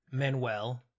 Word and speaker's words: Manuel: So how do you pronounce his name? Lin-Manuel Miranda? – Manuel. Close. So yeah, good enough.